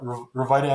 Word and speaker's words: Rviita: Yeah, Revita Energy Tea, it's spelled R-V-I-I-T-A.